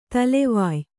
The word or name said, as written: ದಳವಾಯ್